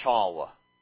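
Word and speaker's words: shva: but the Ashkenazi way of saying this is shawa, all right? Shawa, that wa is schwa, as we call it.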